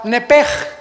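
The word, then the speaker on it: nepej (נפש): La palabra vida, apúntalo en el hebreo, es la palabra nepej. Nepej se escribe nepesh con SH al final y es alma.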